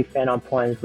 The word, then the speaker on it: Fernand Point: The French, they're all French, but Fernand Point. – Fernand Point. – It is Fernand Point.